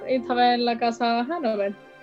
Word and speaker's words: Hanover: Y sí, luego, bueno, pues vamos a tener a Dinastías, de nuevo a la casa Hanover, Hanover, depende de cómo pronunciéis. – Hanover, con... como el pub irlandés.